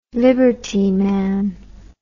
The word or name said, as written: liberty man